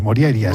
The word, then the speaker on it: Morjaria: Bueno, la J normalmente se pronuncia como I, Morjaria.